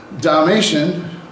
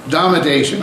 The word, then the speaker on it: Domitian: The second persecution was under another emperor, Domitian, not like the spotted animals, but Domitian.